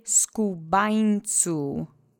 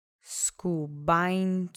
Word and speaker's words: skubańcu: Let's say skubańcu together three times. Accent is on the middle syllable. And remember the ny letter in the alphabet is here too. So soften that N in the middle.